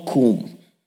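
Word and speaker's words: kum (קום): It's the word kum. It's spelled Q-U-W-M.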